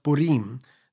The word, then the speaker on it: Purim: The announcing of Purim. It could also be pronounced Purim.